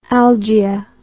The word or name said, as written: algia